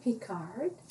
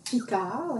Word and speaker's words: Picard: My name is Valerie Picard. Picard, like my friends, they don't like it when I pronounce my name in English.